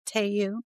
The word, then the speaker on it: Teo Yoo: Haesung is played by Taeyoo. – T-E-O-Y-O-O. And I apologize. – Taeyoo. – Don't know how to pronounce that. – Yeah. – Taeyoo. – Okay. He did very good.